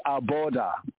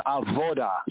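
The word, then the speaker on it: avoda (עבודה): So the Shemona Esrei is the heart of every Aboda. Aboda in the Hebrew is a service, Aboda, service. In modern Hebrew, it's pronounced as avoda. So you might hear one say avoda.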